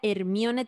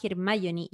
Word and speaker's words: Hermione: Em, también a una paloma que dice, ah, yo leía Hermione textual, quedé para la cagada cuando caché que se pronunciaba Hermione y aluciné mal cuando leí El Cáliz de Fuego.